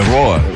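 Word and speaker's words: Roar: É, é, como é que pronuncia? É Roa, né? É, Roa.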